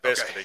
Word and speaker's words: Vescovi: Or, you know, on this team, if you're Santiago Vescovi, you know, did I say that wrong again? – No, Vescovi. Okay. You got it.